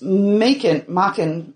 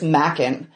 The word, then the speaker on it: Machen: There's Machen, oh, I can never pronounce his name. M-A-C-H-E-N. – Okay. – But it's Welsh, so I'm pretty sure it's Macken.